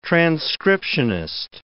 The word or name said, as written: transcriptionist